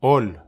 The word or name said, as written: ol